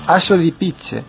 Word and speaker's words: Asso di Pizze: ¿Cómo se pronuncia? ¿Cuál, perdona? – Asso di Pizze, haz de picas.